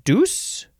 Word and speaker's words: douse: The third form of douse, you can also pronounce it deuce.